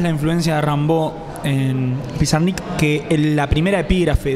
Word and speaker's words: Pizarnik: Se supone que debería decirse Pizarnik, debería ser lo correcto.